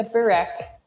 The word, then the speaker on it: burek: Am I pronouncing that right? – Yeah, burek. Yep.